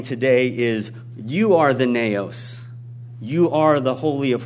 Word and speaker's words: naos: The Greek word, as I've mentioned before here, that's translated temple is naos, and it's spelled N-A-O-S, naos, and it literally means the sanctuary.